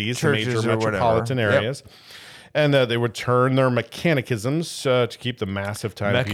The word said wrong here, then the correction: mechanisms: What did I say? – Mechanicisms. – That's the right way to say That's not a word. – Mechanicisms. – Mechanisms.